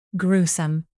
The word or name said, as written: gruesome